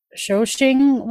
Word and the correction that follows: Shaoxing: Shaoxing wine. – Shaoxing.